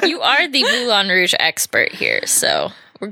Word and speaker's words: Moulin: Is it Moulin? Am I saying it right? – Yeah, it's Moulin.